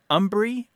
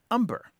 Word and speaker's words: hombre: And this is that there has been a long history in the United States of a very anglicized pronunciation of the Spanish word hombre, meaning man. And those two pronunciations are umbre or umber.